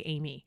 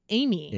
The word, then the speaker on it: Amy: No one ever knows what I'm saying when I say Amy. It's three letters. – Yeah. – They're always like, when then they don't know how to say it back. I'm like, Amy.